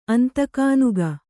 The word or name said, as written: ಅಂತಕಾನುಗ